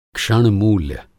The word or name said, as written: क्षणमूल्य